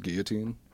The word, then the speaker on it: guillotine: Am I the only one pronouncing it as guillotine? – Guillotine? – I think you're right. That's the right way to say it.